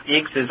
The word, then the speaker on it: IAX: And are you, the word you're saying, is that the one that's spelled IAX? – That's it. EECS is IAX.